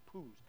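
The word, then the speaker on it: poos (铺): They're called poos, P-U, poo. That's how you pronounce it, poos.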